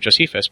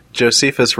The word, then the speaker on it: Josephus: No, it just occurred to me that Josephus rhymes with Bosephus.